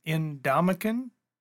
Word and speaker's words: Ndamukong: Ndamukong.